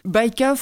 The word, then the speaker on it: Bajka: Baïka, phonétiquement, c'est du polonais. Ça vient du polonais. Ça s'écrit B-A-J-K-A. Et ça veut dire fable, ça veut dire histoire. Voilà, ça répond à ta question. – Oui, bah, parfait, parce que justement, ce n'est pas la prononciation anglaise. Vraiment une prononciation polonaise, en fait, Baïka.